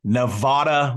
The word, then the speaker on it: Nevada: And I grew up my entire life thinking Nevada was the name of Nevada because Nevada, Missouri is how they all pronounce it there. So I'm going to come to your defense. There are other parts of the United States where they pronounce it differently.